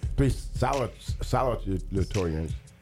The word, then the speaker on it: salutatorians: Three salutatorians. Say that again. – And thank you very much. How you say it again? – Salutatorians. – I got a GED, man. Salutatoriums, 2006, 17, and 20.